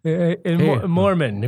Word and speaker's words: Mormon: The moron religion. – Yes, exactly. – Did I pronounce that right? – Yes, exactly. Mormon, if you'd like to be a Mormon.